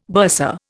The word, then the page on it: bursar: Indian